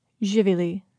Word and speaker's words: živjeli: You say, živjeli.